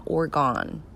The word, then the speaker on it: Oregon: Gulf Coast to Oregon. And I say it like that so that people understand it's not Oregon. It's Oregon. Like an organ in your body. Like, not like Oregon, which almost sounds Canadian.